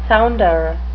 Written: sounder

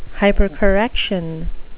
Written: hypercorrection

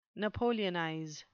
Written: napoleonize

